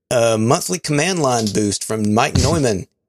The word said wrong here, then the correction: Neumann: it's Newman. Mike Newman. I always mispronounce it.